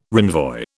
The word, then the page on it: renvoi: British English